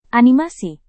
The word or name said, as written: animasi